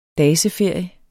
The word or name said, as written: daseferie